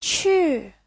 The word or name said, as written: qu4